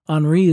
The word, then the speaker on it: Henri: Henri is spelled H-E-N-R-I, and that's how Dick Zimmerman pronounced it at the festival, and that's good enough for me.